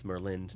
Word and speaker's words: Merlind: Not Merlin, like the magician, because that doesn't have a D. It's Merlind, not Merlin.